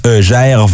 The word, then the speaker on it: Gervase: C'est, euh, la réponse qu'on cherchait, c'est à Gervais, Gervaisi. Bah. – Je sais pas comment ça se prononce. Comme, Ricky? – J-G-E-R-V-A-S-E. Gervase.